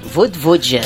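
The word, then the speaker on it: Vojvoda: Como é que é a pronúncia? – Vodvodja.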